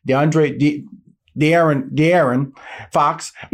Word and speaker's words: De'Aaron Fox: The agency represents De'Aaron Fox of the, that's just correct, I said it right.